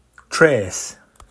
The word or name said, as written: Treth